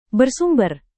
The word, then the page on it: bersumber: Indonesian